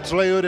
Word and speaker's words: tlayuda: Tlayuda is basically a tostada, and it had sashimi on it, and what else? – Please pronounce it tlayuda.